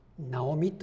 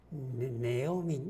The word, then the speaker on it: ナオミ: 際的に通じる名前にしたいと思ったらナオミにしてくださいこれはどっちでも通用します日本でもアメリカでもただし白人の女性なんかがナオミという字を見てもその通りには発音してくれませんネオミネオミっていう感じの発音に近づきますからちょっと音は違う